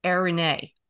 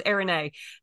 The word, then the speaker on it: Eirene: And the word in the New Testament for peace is a word that is Eirene. Am I saying that right, Debbie? Because sounds close enough for me.